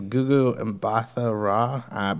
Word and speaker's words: Gugu Mbatha-Raw: Yeah, I think it's Gugu Mbatha-Raw, but I could be wrong as well.